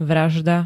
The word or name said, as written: vražda